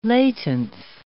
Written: laitance

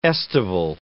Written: estival

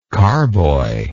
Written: carboyed

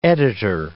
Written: editor

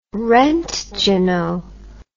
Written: roentgeno

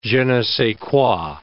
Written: je ne sais quoi

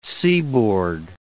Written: seaboard

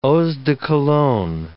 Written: eaux de cologne

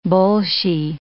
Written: bolshy

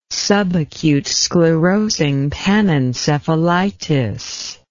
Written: subacute sclerosing panencephalitis

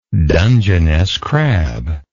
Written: dungeness crab

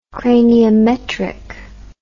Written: craniometric

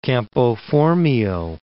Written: Campo Formio

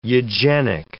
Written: eugenic

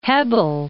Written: Hebbel

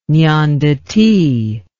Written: nanduti

